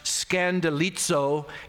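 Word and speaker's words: scandalizo (σκανδαλίζω): Well, the Greek word that is translated fall away here is pronounced scandalizo.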